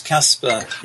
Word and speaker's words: Casper: It's Casper. Yeah. – Wait a minute. You always say K-Spa. – Well, no. That's only when you're in New Zealand.